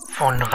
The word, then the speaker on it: Phaune: phone radio. I mean, phone radio. It's spelled P-H-A-U-N-E. But it's pronounced phone.